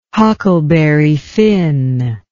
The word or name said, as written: Huckleberry Finn